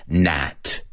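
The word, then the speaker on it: gnat: Well, this word has a silent G. But it's pronounced. let me work on my pronunciation. It's pronounced nat. Nat.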